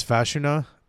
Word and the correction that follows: Fashanu: Let me look that up before I fucking butcher it. Uh, Fashanu. Olumuyiwa Fashanu out of Penn State.